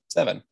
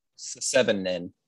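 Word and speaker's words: Se7en: Much like this movie is just pronounced Seven. – All right. – Not Seven-in.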